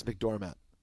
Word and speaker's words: McDormand: Isn't it McDermott? – No. – Frances McDormand. – McDormand. – It's actually, if you want to pronounce it correctly, it's Frances McDormand.